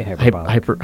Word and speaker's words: hyperbolic: You can say hyperbolic. – Hyperbolic. Yeah. There we go.